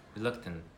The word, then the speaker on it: reluctant: You can say it like that, reluctant. Don't pronounce the last T, please. It's in American accent, but in British accent, you can say the two T's, reluctant.